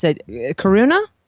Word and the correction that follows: Kiruna: Uh, Kiruna. – Okay, there you go.